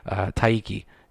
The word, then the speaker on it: taiyaki: And I think it's called Kiaki. I want to say Miyaki? Kiaki. Miyaki. No? Taiki. Sorry. Oh, God, I butchered that. Taiki. T-A-I-Y-A-K-I.